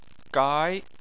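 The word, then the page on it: gai: tone 2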